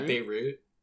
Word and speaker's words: Beirut: Berut explosion. Have you noticed this is all, like, sad news? Like, where's the Beirut? Where's the Beirut? Yeah, Beirut.